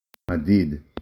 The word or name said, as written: מדיד